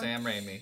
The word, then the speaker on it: Raimi: And I think part of that is Sam Raimi. Like, I feel like I'm saying it wrong. – Sam Raimi.